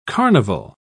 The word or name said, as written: carnival